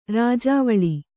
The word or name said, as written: ರಾಜವಾಳೆ